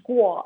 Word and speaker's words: Guo: No, Miles Guo, he's the founder of the Chinese whistleblower movement. – Ah, good. So, say his name one more time. – Miles Guo, last name spelled as a G-U-O, and he's the founder of the Chinese whistleblower movement.